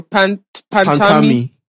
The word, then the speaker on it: Pantami: Pantami.